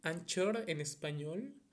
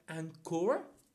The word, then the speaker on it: Anchor: Así que yo entro a esta mm, aplicación que se llama Anchor, Anchor en español, pero bueno, tengo entendido que se pronuncia Anchor.